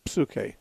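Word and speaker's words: psuche (ψυχή): which is spelled with a silent P at the beginning, psuche, like psychology has.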